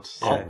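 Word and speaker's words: Olyphant: Oliphant. – Oliphant? I think, like, Oliphant? I just listened to, the commentary. He said it like Oliphant.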